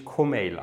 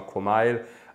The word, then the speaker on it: Komeil: Ähm, ja, da haben viele Leute, äh, fragen, das fragen mich Leute oft und das Ding ist so, mein Name also spricht sich eigentlich Co-Mail aus, also Co und dann Mail wie E-Mail.